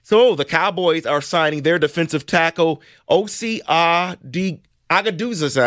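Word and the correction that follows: Odighizuwa: I always mess that name up. – Jesus. – Odigizua. – Odigizua. Yep.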